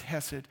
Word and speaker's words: hesed: The Hebrew word here is in this verse is hesed. That's how it's pronounced, hesed.